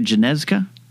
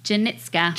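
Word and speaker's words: Janicza: Janeska? – Janitska.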